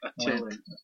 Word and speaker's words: Attilan: Atlanta. – No, it's Attilan.